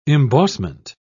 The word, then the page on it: embossment: British English